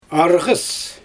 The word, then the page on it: arGys: Xyzyl